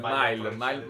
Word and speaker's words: Miles Morales: Lui dice Miles Morales. Miles Morales. È spagnoleggiante. – Ci sta. – Miles Morales. È il Miles Morales.